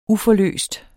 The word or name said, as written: uforløst